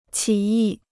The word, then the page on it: 起义: qǐ yì